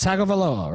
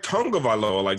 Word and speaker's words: Tagovailoa: It's Tagovailoa, right? – No, I thought it was like Tungavailoa. – Tungavailoa. Yeah. – But where's the N anyway? – There is no N.